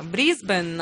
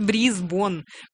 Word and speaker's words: Брисбен: Кто говорит Брисбон? Кто говорит Брисбон? Кто говорит Брисбен? Кто говорит Брисбейн? На самом деле, вы понимаете, что по-английски оно пишется B-R-I-S-B-A-N-E.